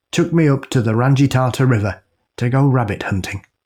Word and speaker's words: Rangitata: This one summer, Uncle David took me up to the This one summer, Uncle David took me up to the Rangitata River to go rabbit hunting. I'll do that again. – I mean, I don't know how it's pronounced either, so. – Rangitata.